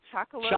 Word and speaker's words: chocolope: Durban Poison, Ghost Train Haze, I guess it's chocolope. I don't know, like cantaloupe, but chocolope, I guess. – Chocolope. – Oh, chocolope. There we go. – Extra smooth. Extra smooth. Chocolope. That's how you pronounce it.